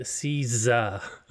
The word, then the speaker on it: CISA: It has a sizzle to it, CISA. We asked. Well, I always called it CISA. But I think they used the term sizzle for the way you pronounce the S, CISA.